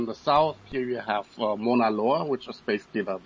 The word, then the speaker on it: Mauna Loa: And I can already assure you, you pronounced Mauna Loa correctly.